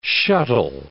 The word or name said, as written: shuttle